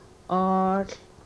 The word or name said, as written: oohl